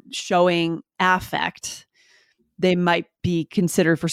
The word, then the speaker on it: affect: but it's almost always pronounced affect. We change the vowel.